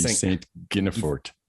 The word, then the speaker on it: Saint Guinefort: English pronunciation would be Saint Guinefort.